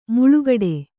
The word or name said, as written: ಮಲಗಟ್ಟು